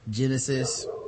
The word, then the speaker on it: Genisys: Terminator 5, Terminator Genesis, and it's spelled Genisys. Genesis.